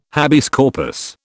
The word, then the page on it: habeas corpus: British English